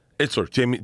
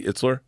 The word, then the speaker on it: Itzler: I mispronounce his name. Jesse Seitzer. He lived with Cam Haynes. He's Itzler. Jesse Itzler.